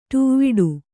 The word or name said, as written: ಡವುಡೆ